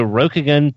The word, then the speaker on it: Rokugan: joins them to discuss their foreway into Rokugan part 1 of 2, which I probably butchered.